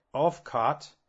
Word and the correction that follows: Ulfkotte: It's Ulfkotte.